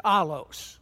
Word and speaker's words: allos: So you would spell this A-L-L-O, that's an omicron, S-sigma. So it's pronounced allos.